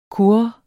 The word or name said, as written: kurre